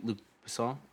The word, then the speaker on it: Luc Besson: He being Luc Besson? – Yeah.